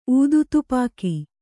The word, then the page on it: ಊದುತುಪಾಕಿ: ūdutupāki